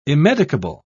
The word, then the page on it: immedicable: British English